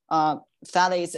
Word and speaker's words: phthalates: Okay, so the next one that we hear a lot about, like phthalates.